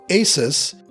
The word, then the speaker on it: ASUS: Perhaps you've been mispronouncing it like me as ASUS or ASUS, but it is actually pronounced ASUS, because it is named after the ancient Greek mythological winged horse Pegasus.